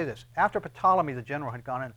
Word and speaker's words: Ptolemy: Ptolemy, General Ptolemy. And as he takes over this particular region, you spell that with a P, P-T.